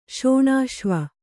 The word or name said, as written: ಶೋಣಾಶ್ವ